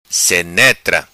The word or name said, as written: Senetra